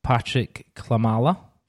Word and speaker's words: Patryk Klimala: We're here to talk about Patryk Klimala. Firstly, is that how you say his name? – Yeah, that's pretty much bang on.